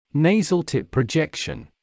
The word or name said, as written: nasal tip projection